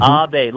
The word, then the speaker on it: Abade: Abade. Okay, there we go. – Yes, Abade. Yes. Mm-hmm. Abade, like a serenade, but it's an Abade.